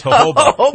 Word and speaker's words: jojoba: I used to call it jojoba, but it's not. It's jojoba. – Yeah, it's spelled J-O-J-O-B-A.